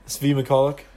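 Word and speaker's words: Svi Mykhailiuk: And then second, I've been practicing this pronunciation all offseason. Svi Mikaluk. Is that about right? – Yep. Svi Mikaluk. That's how I say it.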